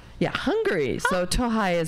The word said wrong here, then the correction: Tokaj: is actually spelled T-O-K-A-J, but I assumed it's pronounced Tohai. – Tokai. – Yeah. Okay. All right. See? So I was slightly off.